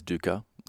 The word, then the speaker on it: dukkha: There's dukkha, there's suffering, there's... – Rhymes with dookie. – Right, with an A on it.